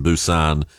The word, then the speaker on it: Busan: I was disappointed when I discovered that the South Korean city of Busan was pronounced Busan because it's spelt the same as Susan but with a B, and I just loved the thought that there was a city named Busan.